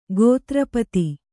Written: ಗೋತ್ರಪತಿ

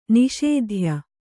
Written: ನಾಸತ್ಯ

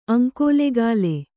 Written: ಅಂಕೋಲೆಗಾಲೆ

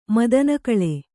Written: ಮದನಕಳೆ

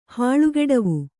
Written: ಹಾಳುಗೆಡವು

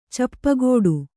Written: ಚಿಪ್ಪುಗಟ್ಟು

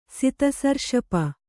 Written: ಸಿತಸರ್ಷಪ

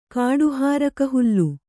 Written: ಕಾಡುಹಾರಕಹುಲ್ಲು

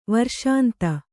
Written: ವರ್ಷಾಂತ

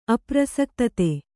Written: ಅಪ್ರಸಕ್ತತೆ

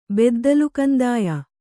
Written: ಬೆದ್ದಲುಗಂದಾಯ

